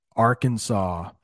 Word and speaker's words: Arkansas: Arkansas. That's how you pronounce that state, by the way.